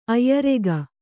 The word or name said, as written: ಆಯುರೇಖೆ